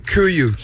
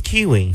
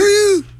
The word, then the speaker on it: Kuiu: Kuyu. – He doesn't know how to pronounce it. – K-U-I-U.